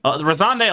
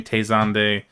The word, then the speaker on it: Razande: Razande, a lot of people didn't know how to pronounce it when they saw it. They didn't know how to spell it. They would keep saying Tezande.